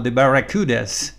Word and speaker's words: The Barracudas: Se llaman The Barracudas. Pronunciado bien en inglés sería algo así como The Barracudas.